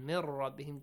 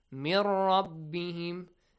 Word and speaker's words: مِنْ رَبِّهِمْ: Mir rabbihim, just merge them and do not pronounce, and do not produce a nasal sound. Mir rabbihim, no. No nasal sound. Just mir rabbihim. Make sure ra has a heavy sound.